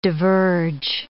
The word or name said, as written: diverge